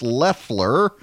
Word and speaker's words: Loeffler: I've been saying, I'm going to have to get out of this habit now, I've been saying Loeffler, but it is pronounced Loeffler.